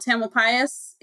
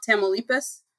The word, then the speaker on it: Tamalpais: Because I was so focused on birds, but I used to call Mount Tamalpais in Northern California, Mount Tamaulipas. – Oh, well, as a birder, that makes sense. Yes, well, I was convinced that I was saying it right and everybody else was wrong. And I pushed back a little bit and finally you're just straight up wrong here.